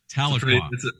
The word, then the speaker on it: Tahlequah: Tahlequah.